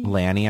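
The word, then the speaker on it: LANY: Lanny, I think is how you say it. – Yeah. Yeah.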